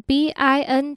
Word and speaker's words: been: 首先第二个字 it's been been 这个字你可以念成 bin 的音